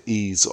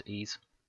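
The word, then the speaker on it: Ys: I'm pretty sure the guy pronounced it Ease Origin. – Yes, he did, Ease.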